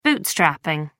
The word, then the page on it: bootstrapping: American English